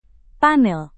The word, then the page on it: panel: Indonesian